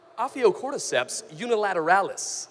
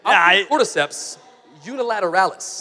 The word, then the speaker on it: Ophiocordyceps unilateralis: There's a certain kind of ant that when it dies, there's a certain fungus known as Ophiocordyceps unilateralis. – Oh, that sounded right. – You nailed it. – Ophiocordyceps unilateralis.